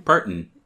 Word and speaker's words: Parton: Parton. Like Parton? Dolly Parton.